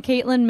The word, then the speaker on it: Kaitlin: I'm Caitlin Mamie, not Mammy.